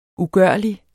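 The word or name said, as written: UGØRLIG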